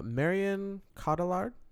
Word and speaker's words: Marion Cotillard: Marion Cotillard.